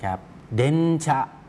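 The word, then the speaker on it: เด น ชะ (電車): เดนชะเดนชะตรงเดนนั้นออกเสียงยาวนะครับถ้าออกเสียงช้าๆจะออกเสียงว่าเดอึนะครับแต่ออกเสียงรวบยาวก็เลยออกเสียงว่าเดนส่วนตัวชะที่อยู่ข้างหลังนั้นออกเสียงสั้นนะครับ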